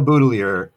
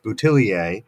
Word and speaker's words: Boudelier: And for those who are wondering about my pronunciation, because it looks like it ought to be pronounced Boutillier, Clive Boudelier was from the Canadian province of Nova Scotia, which has a large population of people who are of French descent, but who have long since abandoned French language. And so when I corresponded with the family, they let me know that they pronounce the family name Boudelier. And so I follow suit.